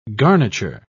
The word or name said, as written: garniture